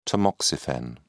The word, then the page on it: tamoxifen: British English